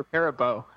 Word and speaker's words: Perabo: Number one, was she Perabo or Perabo? How do you say her name? – I learned at some point years ago that it's actually pronounced Perabo, but maybe I'm wrong about that.